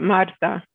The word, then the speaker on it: Marta: My name is Marta Rivera Diaz. And it's just said just like that, Marta.